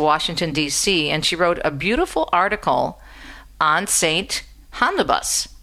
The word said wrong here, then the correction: Homobonus: Homobonus, that's his name, Homobonus, that's how you pronounce his name.